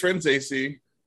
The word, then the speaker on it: Franzese: Hi, it's Franzese. – Franzese. Okay, well, I mean, I was close, right? – Yeah, pretty close. RuPaul helped me tell people how to say it. It's I don't have my own air conditioner. I have to use my friend's AC.